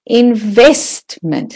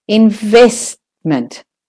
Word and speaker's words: investment: Investment. So the vest is the stressed syllable. Investment.